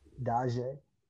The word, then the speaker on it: Dazay: Daje. – Daje, spelled D-A-Z-A-Y, with a little accent over the Z.